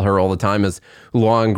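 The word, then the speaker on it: Lujan: It must be like her old last name or something that she wants to use because it has a silent J in it. And so they want to make sure, not silent J, it's a, you know, what do you call it? – A hard J? – Well, it's not a, her name isn't Lou Jan. I'm sure they say Luan.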